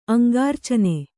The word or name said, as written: ಅಂಗಾರ್ಚನೆ